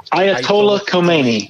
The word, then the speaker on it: Ayatollah Khomeini: Ayatollah Khomeini.